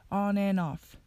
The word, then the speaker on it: on and off: off のところで on と and がくっついて on and となるのと続けて言う時には and の d が消えてしまいます and の d がなくなると an になるので off とつなげると an off となりますすべてをつなげて発音すると on and off となります o k repeat after me. Is the pain constant or on and off? Is the pain constant or on and off?